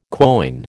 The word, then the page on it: quoin: American English